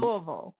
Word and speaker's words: Louisville: Louisville. Say it like you have rocks in your mouth, Fred.